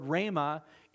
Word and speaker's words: rhema: It's spelled R-H-E-M-A, rhema.